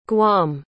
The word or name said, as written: guam